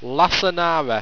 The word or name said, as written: lasanara